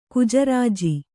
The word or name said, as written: ಗಜರಾಜ